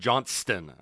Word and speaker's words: Johnston: Ruff always gets on my case because I don't pronounce the T in Johnston. I just call Chris Johnston.